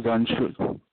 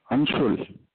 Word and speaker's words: Anshul: Yeah, this is Anshul. – What's your name? – Anshul. – Oh, I'm not quite getting that, but... – I can't understand what you're saying either. – Spell it, please. – It's Anshul, A-N-S-H-U-L. – A-N-S... – H-U-L.